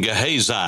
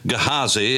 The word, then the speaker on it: Gehazi: We call him Gehazi. Now remember, there is no long I as such in the Hebrew language, and Gehazi is the way that that would be pronounced or something akin to that.